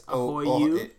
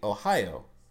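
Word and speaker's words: Ohio (おはよう): But I texted Danny Bivens of the Famicast, because I figured he might know how to say this, and it's pronounced, he says, it's in his exact words, it's pronounced like the state Ohio, and it just means morning, like morning, and if it were Ohio gozaimasu, that would mean good morning.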